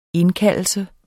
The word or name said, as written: indkaldelse